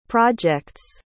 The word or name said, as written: projects